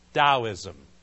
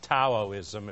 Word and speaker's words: Taoism: And it is pronounced Taoism, like D-O-W-I-S-M, Taoism, not Taoism. If you say, I used to say that to start with, Taoism, because I was learning too. But it's Taoism.